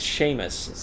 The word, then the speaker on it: Seamus: Pronounced Shay space Mus. – It can be. Okay. – It's Seamus.